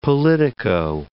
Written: politico

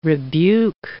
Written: rebuke

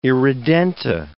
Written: irredenta

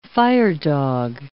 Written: firedog

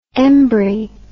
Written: embry

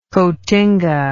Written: cotinga

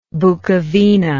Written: Bukovina

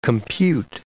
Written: compute

